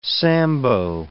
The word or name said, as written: sambo